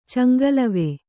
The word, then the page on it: ಚಂಗಲವೆ: caŋgalave